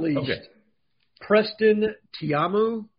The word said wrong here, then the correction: Taumua: Is that how you say it, Cole? – I've been saying Taumua. – Taumua, you're probably right.